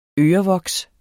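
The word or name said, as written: ØREVOKSET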